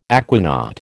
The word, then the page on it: aquanaut: American English